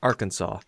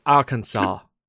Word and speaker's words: Arkansas: And Huvian, it's not pronounced Arkansas. It's Arkansas.